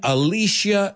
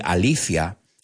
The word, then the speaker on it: Alicia: So, yes, the movie is The Crush, starring Alicia Silverstone. Yeah, I know you guys say Alicia. We say Alicia. that's the pronunciation over here.